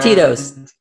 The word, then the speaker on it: Tito's: Tito's.